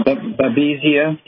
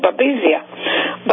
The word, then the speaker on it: Babesia: I'll spell it B-A-B-E-S-I-A. – Yeah, Babesia.